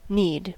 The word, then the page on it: need: American English